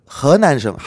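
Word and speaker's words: 허난성 (河南): 자 그리고 7위는 그 허난성, 한국어 발음은 하남성인데 허난성의 호이면이라고 하는 면이 있습니다.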